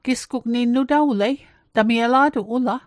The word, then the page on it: nutawlet: Mi'gmaq